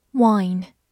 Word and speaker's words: wine: Wine, W-I-N-E, wine，字母 i 发的本身的音 i，末尾的 e 不发音。